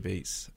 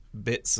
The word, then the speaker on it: Beetz: Or however you pronounce it, because I think the world's been saying Zazie Beetz and we've just discovered that it's actually Zazie Bits or something like that.